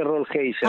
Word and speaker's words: Rollheiser: O Roleiser, no sé cómo se pronuncia, Rolleiser, ¿no? – Yo le digo la familia le dice Rollheiser.